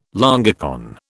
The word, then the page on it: longicorn: British English